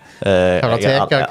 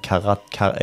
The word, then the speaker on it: Karateka: Hvordan er det du uttaler det? – Karateka, Karateka. Jeg sa Karateka, jeg var liten.